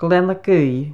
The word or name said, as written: GLEANNAGAOIDH